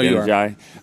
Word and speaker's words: Adeniji: Adeniji. I don't even know if I'm saying that right. – No, Adeniji. You are.